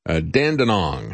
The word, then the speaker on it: Dandenong: especially in Dandenong, I think is the way you pronounce that.